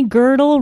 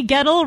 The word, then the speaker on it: Gettle: and Donnie Girdle rhymes with Myrtle. Just kidding, Donnie. Gettle rhymes with metal.